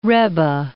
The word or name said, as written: rebbe